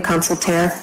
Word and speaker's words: Consultair: And am I pronouncing that correctly, Consultair? – Sure. And you know, I found out about this hearing by means of subpoena served at my home at 11 o'clock Sunday morning.